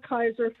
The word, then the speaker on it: Kiser: So Melba Kiser for Noblesville School Board, and that is spelled K-I-S-E-R, and Misty Ray for Noblesville School Board.